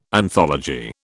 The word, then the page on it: anthology: British English